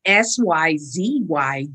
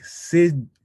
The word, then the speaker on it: Syzygy: it's weird for me to pronounce too, but it's like Syzygy. – Syzygy. Oh, yeah. Okay. So it's almost like synergy, but it's Syzygy.